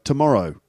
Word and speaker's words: tomorrow: Consider, for example, the way that English native speakers swallow the first syllable of the word tomorrow. So we get tomorrow, not tomorrow, but tomorrow.